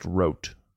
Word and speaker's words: Roth: Spelled Roth, but it's pronounced Rote.